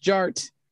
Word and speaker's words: Jart: And my name is Jared, aka DJ Art, with two T's for a double dose of that tink tink. The D is silent, so it's just Jart.